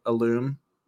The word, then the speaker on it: alum: So when I see Alum, I say Alum, like Alum Creek, but that's not how you pronounce it. It's like Alum.